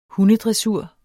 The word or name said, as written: hundedressur